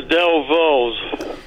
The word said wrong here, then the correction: Dovells: Del Vos. I'm not saying it right. – That's all right. You know it's the Dovells, and I'll give it to you for that.